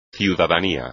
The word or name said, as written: ciudadanía